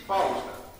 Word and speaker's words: Φάουστα: Η Φάουστα προφέρεται λατινιστή Φάουστα και πραγματικά ήταν η Φάουστα της οικογενείας.